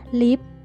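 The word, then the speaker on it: lift: lip สะกดนะคะ L I F T อ่านว่า lip เมื่อทับศัพท์มาแล้วนะคะตัว t ในคำว่า lip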